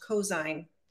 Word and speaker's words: Cozine: And my last name is pronounced Cosine.